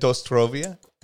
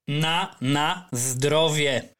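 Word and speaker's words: Na zdrowie: Wait, how do you say it? Dostrovia? – Na zdrovie. – Na zdrovie. – Which means for healthiness, something like that, for healthiness.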